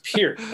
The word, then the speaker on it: Peart: And he's like, no, it's Peart.